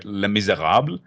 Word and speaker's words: Les Misérables: Les Misérables.